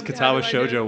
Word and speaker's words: Katawa Shoujo: This is not as risky as Katawa Shoujo was, like, 10 years ago.